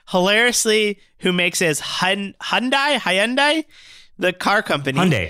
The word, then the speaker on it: Hyundai: Like Hyundai, like Sunday. – Hyundai. Oh, yeah. I always say Hyundai. – Yeah, that they had a commercial that taught you how to say it here in the States.